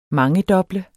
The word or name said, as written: mangedoble